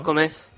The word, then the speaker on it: comés: No, justo él dijo, vos comes o tú comes, lo loco, cuando usamos vos, se acentúa en la segunda sílaba del verbo. – Claro. – Vos comés.